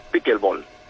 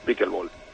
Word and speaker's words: Pickleball: Sí, es Picklet Ball. Porque aunque se escribe como bien lo has pronunciado, el nombre es Pickleball, que es algo más sencillo de decir, ¿no? – El Pickleball.